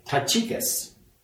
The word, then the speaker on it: Tychicus: And his name is Tychicus. And that is the way you're supposed to pronounce it. I looked it up.